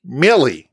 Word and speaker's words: Milli Vanilli: I was talking to my daughter today about Melly Vanelli. Did I say that right? – I don't think so. – The guys that were limp syncing. Melly Vanelli. – I don't think there's an E in there. – Menelli? – Millie.